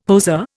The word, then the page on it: poseur: Indian